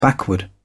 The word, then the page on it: backward: British English